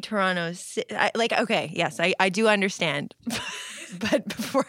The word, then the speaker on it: Toronto: And I'm making a point of saying Toronto, because in Toronto, we just say Toronto.